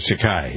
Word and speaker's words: Takei: George Takei, yes.